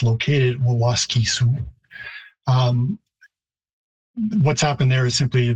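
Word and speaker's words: Waskesiu: So Waskisu, Waskisu again in typical English pattern, if there is a stress on the third last syllable, like Canada, you tend to reduce the second syllable. So Waskisu, it would be a longer vowel in Cree, even though the stress is on the first syllable.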